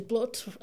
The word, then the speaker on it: blut: Like, house in Tok Pidgin is house, but it's spelled H-A-U-S, which is like the German way of spelling, and like blood is blut, like B-L-U-T.